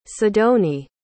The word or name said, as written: sidonie